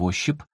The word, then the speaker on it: ощип: Тем более, что слово «ощип» всегда произносилось в русском языке с ударением на второй слог.